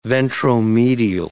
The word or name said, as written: ventromedial